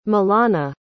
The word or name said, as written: milana